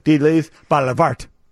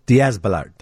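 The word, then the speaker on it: Díaz-Balart: So you have Lester Holt and Savannah Guthrie and Telemundo's José Díaz Balart. That's not how you spell it, pronounce that, is it? – Díaz Balart, I believe.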